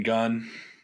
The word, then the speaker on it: Gunn: No, because in God, in Gunn. – In Gunn. – One syllable starts with a G.